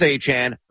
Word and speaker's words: Etienne: Again, it is pronounced Travis Etienne.